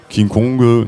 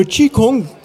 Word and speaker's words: Qigong: Qigong, non, c'est pas Qigong en plus. Hein. – Alors c'est le Qigong. Qigong qui phonétiquement se prononce Qigong.